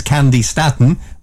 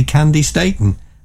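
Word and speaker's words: Candi Staton: Everybody thought it was Candy Staten, but it's actually Candy Staten.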